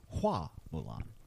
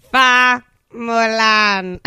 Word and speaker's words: Hua Mulan: I think her name in Chinese, like in the from the original tale, is pronounced Hua Mulan. – Fa Mulan.